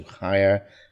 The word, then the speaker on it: hire: We've got the H word hire, and often people drop the H, but make sure in this case you pronounce it, to hire, and then a decorator with four syllables, decorator.